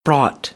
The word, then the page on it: brought: American English; ɑː